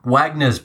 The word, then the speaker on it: Wagner: You've kind of butchered his name. I think it's Wagner. I think it's Wagner. Wagner. They pronounce it with a V for some reason.